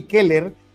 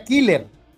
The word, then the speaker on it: Keller: No, dice Juan, me acuerdo que Orbañanos en eliminatorias a Casey Keller le decía Killer, pero por mala pronunciación, o sea, Casey, Killer.